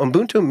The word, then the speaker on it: Ubuntu: Ubuntu, it's spelled U-B-U-N-T-U, but it's pronounced Ubuntu, is a philosophy that came to have special meaning to me, again, as I worked in sub-Saharan Africa and traveled.